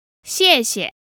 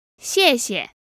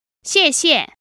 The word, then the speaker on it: 谢谢: So this word is made up of two characters, and it's actually the same character said twice, both fourth tones meaning thanks. – 谢谢. – Now, depending on where you are, you may hear the second character pronounced with a neutral tone, giving us 谢谢.